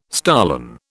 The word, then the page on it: stolon: British English